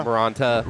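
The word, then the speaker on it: Moronta: Maronta? – Maronta.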